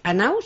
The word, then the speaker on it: Hanau: Que es Anau, ¿se pronuncia? – Exactamente.